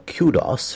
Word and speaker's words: kudos: The word actually appears as K-U-D-O-S in the dictionary, right? K-U-D-O-S, and the British pronunciation is kudos, kudos. The American pronunciation is kudos, kudos. Just a slight variation there.